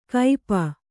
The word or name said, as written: ಕೈಪ